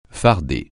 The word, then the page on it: farder: francais